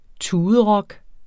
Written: tuderock

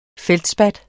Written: feldspat

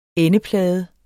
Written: endeplade

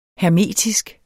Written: hermetisk

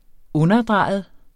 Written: underdrejet

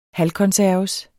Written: halvkonserves

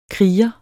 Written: kriger

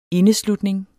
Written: indeslutning

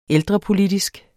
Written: ældrepolitisk

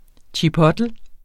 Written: chipotle

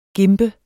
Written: gimpe

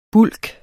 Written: bulk

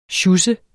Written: sjus

